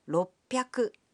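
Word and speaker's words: 六百: 600 becomes ropyaku.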